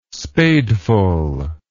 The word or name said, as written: spadeful